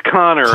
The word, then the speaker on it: Kanner: Or I think, is that how you pronounce his last name, Kanner? – It's Connor, actually.